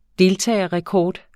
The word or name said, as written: deltagerrekord